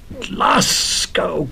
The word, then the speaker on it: Glasgow: I had said Glasgow. – Glasgow, Glasgow, yes. Have you pronounced it like it ever since? – I certainly have.